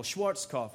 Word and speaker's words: Schwarzkopf: The general Schwarzkopf.